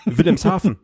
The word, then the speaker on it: Wilhelmshaven: Du, du, mein Ortsnamen hast du auch noch falsch ausgesprochen. Wilhelmshaven. Das S war weg, aber.